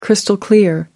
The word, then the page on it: crystal clear: British English